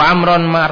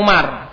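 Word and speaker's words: Amr (عمرو): Fana soha fi'lun madin, wa zaidun fa'ilun marfu'un, wa alamat rafi ad-dhammatu ad-dhahiratu ala akhirihi, wa amrun maf'ulun bihi mansubun, wa alamat nasbihi al-fathatu ad-dhahiratu ala akhirihi. Ini Amr ya, bacanya bukan Umar. Kenapa? Karena Umar mamnu' minas sharaf, enggak mungkin ada alifnya. Mahum, bacanya harus Amr.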